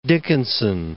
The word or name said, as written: Dickinson